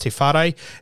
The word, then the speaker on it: Tewhare: I believe it is pronounced Tifare. It is T-E-W-H-A-R-E.